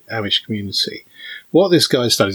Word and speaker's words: Amish: Amish or Amish? – it's Amish. I did check the ah.